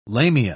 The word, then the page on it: lamia: British English